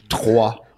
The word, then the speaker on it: Troyes: Yeah, well, it's an R, but that's a French R. It's really hard to say. It's Troyes.